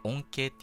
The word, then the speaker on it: 音形: この音の形と書いて音形って読むんですけど、音形が上上と上がっていく音形なんじゃないかなと。